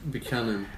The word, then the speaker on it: Buchanan: Buchanan.